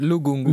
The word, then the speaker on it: Lugungu: Glody Logungu, zeg ik dat goed? Nee, Lugungu. Lugungu, eh, de, de cabaretier van Comedy Train.